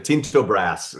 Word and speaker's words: Tinto Brass: Tinto Brass, am I saying that correct? – Yeah, Tinto. Depending on your country of origin, you'll pronounce it differently. But Tinto Brass is how...